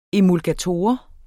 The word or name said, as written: emulgator